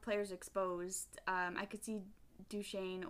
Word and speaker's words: Duchene: Duchene.